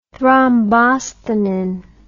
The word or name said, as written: thrombosthenin